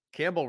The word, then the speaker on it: Campbell: Campbell rhymes with gamble.